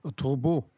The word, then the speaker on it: Turbot: Turbo, also mit T hinten geschrieben.